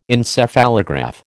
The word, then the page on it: Encephalograph: American English